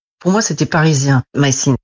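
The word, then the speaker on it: My Scene: Donc, ils ont essayé de riposter en sortant une nouvelle série qui s'appelle Mycène ou Mycine, si on le prononce en anglais.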